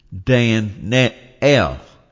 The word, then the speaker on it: Daniel: But in Israel, the correct way to pronounce that name is Dan-el. You always put the accent on the E-L, the name of God.